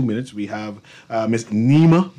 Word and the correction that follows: Naima: Barker. I pronounced it correctly? – Naima. You have one more time. – One more. Naima. Naima Barker, who is the Director of Retail Banking and Small Business at Scotia Bank.